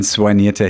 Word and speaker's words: soigniert: Ein soignierter Herr, ein gepflegter Herr. Soigniert? Soigniert, genau. Wie, wie schreibt man das? S-O-I-G-N-I-E-R-T. So wie Monsignor. Hm, so das ähnlich. Ist Französisch. Soigniert.